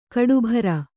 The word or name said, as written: ಕಟ್ಟುಬ್ಬರ